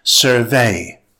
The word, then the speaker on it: survey: When using survey as a verb, stress the second syllable. It's pronounced survey.